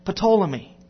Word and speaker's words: Ptolemy: A guy named Ptolemy. P is silent. Anyway, about 85 to 165 AD is when Ptolemy lived.